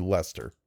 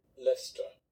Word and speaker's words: Leicester: That's probably not how that's pronounced at all. Forvo. How do you pronounce that word? It's like Lester, right? It's going to be Lester. – Lester.